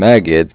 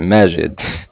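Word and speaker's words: Maged: Okay, I was gonna say, because you pronounced your name Megid, and I know a lot of people who would pronounce it Majid. – Yeah, right? – From Egypt, they don't say G. – They don't say the J, yeah.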